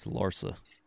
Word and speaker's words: Larsa: Why you keep saying Larson? – That's how you say it, Larsa or Larson? – It's Larsa.